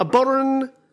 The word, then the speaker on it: bodhran: A boron is what type of instrument? – Oh, a boron. – Yeah. – Boron. – And it's spelt bodhran.